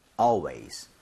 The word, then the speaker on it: always: Always가 아니라 AL은 항상 all에 가깝게 입 모양 벌리셔야 됩니다. Yes. 한번 발음 해볼게요. Always.